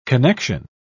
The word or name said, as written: connection